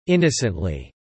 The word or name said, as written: innocently